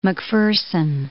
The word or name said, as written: Macpherson